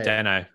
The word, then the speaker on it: Dano: Dano.